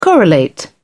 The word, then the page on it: correlate: British English